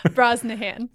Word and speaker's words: Brosnihan: Is Brazaham? – It was close. – Oh, damn it. I'm the worst with last names. – Brosnahan. – Brosnahan. – Yes.